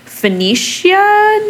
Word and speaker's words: Phoenicia: We think it's pronounced Phoenicia, New York. It looked like Phoenix or like Hooked on Phonics, but then with an A at the end.